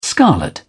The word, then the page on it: scarlet: British English